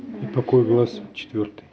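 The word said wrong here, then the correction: Ипакои: Да, только Ипкаи ударение. Ипкаи.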